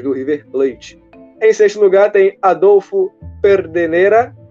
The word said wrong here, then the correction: Pedernera: Pedernera, com 143 gols.